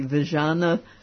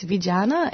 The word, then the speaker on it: Vijana: And I think when you were there, you were really impressed by the work of, and I may not pronounce it correctly, Vijana Africa? – You actually pronounced it correctly. Yeah, it's Vijana, and it is a word in Swahili, which is an Eastern African language, and it means young people.